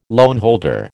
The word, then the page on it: loanholder: American English